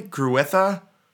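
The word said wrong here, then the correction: Grewishka: It's Gruishka.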